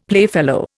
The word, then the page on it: playfellow: Indian